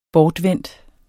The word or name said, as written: bortvendt